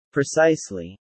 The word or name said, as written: precisely